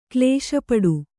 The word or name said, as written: ಕ್ಲೇಶಪಡು